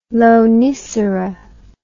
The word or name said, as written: Lonicera